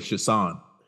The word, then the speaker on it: Chiasson: I think she pronounces it Chiasson. – Chiasson. All right, yeah. We're going to go Chiasson.